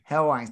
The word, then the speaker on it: Helwani: Helwani, thank you.